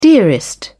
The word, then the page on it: dearest: British English